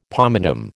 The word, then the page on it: pomatum: American English